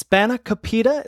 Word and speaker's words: spanakopita: And you mentioned it already, but you have the, it's spanakopita.